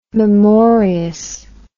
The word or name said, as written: memorious